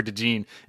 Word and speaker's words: DeJean: And for a little bit, I meant to yell at you about this a couple weeks ago in the last pods, but I was saying Cooper DeJean for like a lot of these episodes, and I found out his name is pronounced Cooper DeJean, and nobody corrected me.